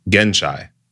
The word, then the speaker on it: Genshai: There is a, a word, it's an, it's, an, it's an Hindu word, an ancient Hindu word um, that's it's Genshai.